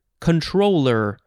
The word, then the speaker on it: comptroller: It's the word comptroller, C-O-M-P-T-R-O-L-L-E-R, but it does look like one of the approved pronunciations is just controller, like your video game controller, but that is not at all how it's spelled. Yeah, I'll just say comptroller because that's what I've heard.